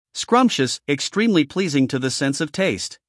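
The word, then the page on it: scrumptious: uk /ˈskrʌmpʃəs/; en-us /ˈskrʌmpʃəs/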